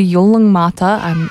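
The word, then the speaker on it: Yolŋu Matha: Five of the popular episodes, including Sleepy Time, The Beach, they're all going to be dubbed into Yolong Mata.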